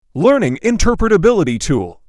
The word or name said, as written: learning interpretability tool